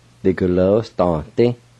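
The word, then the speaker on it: digalvwisdanedi: Now, the WI, the I is silent, and the NE, the E part is silent. Let me pronounce it one more time. The glow, sta, di. The glow, sta, di. You just kind of slip in the W sound and the N sound.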